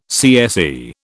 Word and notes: CSE: British English

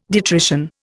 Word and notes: detrition: Indian

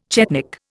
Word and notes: chetnik: Indian

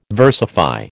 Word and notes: versify: American English

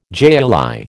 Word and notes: jai alai: American English

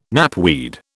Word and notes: knapweed: British English

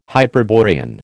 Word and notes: Hyperborean: American English

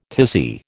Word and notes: tizzy: American English

